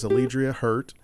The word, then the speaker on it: Alledria Hurt: Her name is Alledria Hurt.